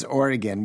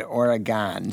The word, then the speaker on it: Oregon: We do not pronounce our town as Oregon. We pronounce it Oregon.